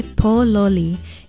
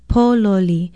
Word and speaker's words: pōloli: In Hawaiian, we say pōloli. It is spelled P-O-L-O-L-I, and the first syllable is stressed, pōloli.